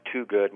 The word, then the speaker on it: Twogood: My name is Dr. Dan Toogood. My last name is spelled like the number 2 and good.